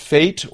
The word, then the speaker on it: fete: According to Merriam-Webster, the word F-E-T-E, which means festival festival. – Celebration. – Fete. – Might be pronounced fate or it might be pronounced fet. That's according to Merriam-Webster's website.